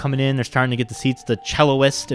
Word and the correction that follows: cellist: if that is the correct pronunciation. Cellist. The cellist is coming